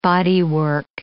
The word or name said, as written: bodywork